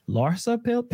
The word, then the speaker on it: Larsa: Michael Jordan's son, Marcus Jordan, reportedly seeing Scottie Pippen's ex-wife, Larsa Pippen.